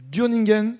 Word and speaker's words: Dürningen: Cathy Chevalier, vous habitez Durningen Oui, très bien. Bien prononcé.